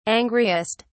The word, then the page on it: angriest: British English